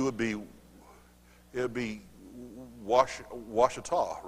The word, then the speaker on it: Ouachita: Ouachita. Are you from Arkansas? There you go, see? It is Ouachita.